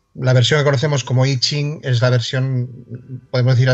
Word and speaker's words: I Ching: como I Ching, pero se pronuncia I Ching y lo hemos visto también en muchas veces, si lo buscamos en internet, escrito como I Ching.